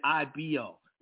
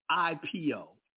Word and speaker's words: IPO: You said it right. I said it wrong. Initial public offering, IPO. I said IBO. I'm used to talking about fucking fucking instruments. I was talking about instruments. It's IPO.